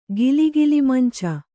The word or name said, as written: ಗಿಲಿಗಿಲಿಮಂಚ